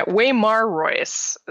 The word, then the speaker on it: Waymar Royce: Um, a character of note in the prologue is um, Sir uh, Wayman Royce. My yeah, Waymar Royce is how that's pronounced.